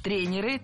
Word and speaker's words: тренеров: Тренеров, неверно. Перед нами, опять же, слово с неподвижным ударением. И двигать это ударение мы не должны. Всегда ударяем «е» в первом слоге. Тренеры, тренера.